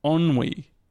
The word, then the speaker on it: ennui: it's spelled E-N-M-U-I, and he's pronounced it ennui.